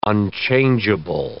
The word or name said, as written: unchangeable